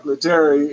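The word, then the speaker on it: Lettieri: Mark Littieri, that's how you pronounce it. Mark Littieri is playing guitar.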